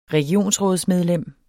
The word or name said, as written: regionsrådsmedlem